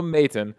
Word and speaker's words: Maton: If you add a Y in there, that's how you pronounce the name. At least that's the way Hamilton says it. So Phil Maton comes in to replace Cam Hill and gives up a home run to Jason Hayward.